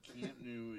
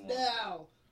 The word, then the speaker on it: Camp Nou: Camp New is... – It's pronounced now! – No, it's Camp New.